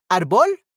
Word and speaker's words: árbol: It doesn't end with N, S, or vowel, and it has a tilde before the uh, the last syllable, so second to last. So this word is called a grave. Okay. Palabra grave. So árbol es grave.